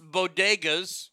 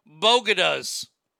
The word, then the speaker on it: bodega: During her speech, she mispronounced bodegas, you know, like the little shops they got up in New York. She called them bogadas.